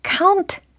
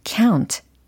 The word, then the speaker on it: count: count 라고 해도 괜찮지만 count, count 네, 이게 좀 더 세련되고 좀 부드럽게 잘 나오는 발음이 되는 것 같아요.